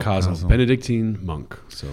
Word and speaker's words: Casel: Although a lot of English speakers call him Kazel sometimes, I believe the proper pronunciation would be Kazel.